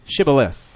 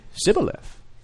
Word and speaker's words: Shibboleth: Then they would say to him, say now, Shibboleth. But he would say, Sibboleth. For he could not pronounce it correctly.